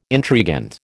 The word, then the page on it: intrigant: American English